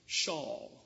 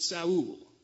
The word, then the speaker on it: Saul: If you know an Israeli with that name, he'll spell it and he'll say it like Shawl with an H. If you have Spanish-speaking friends, they'll pronounce it Saul.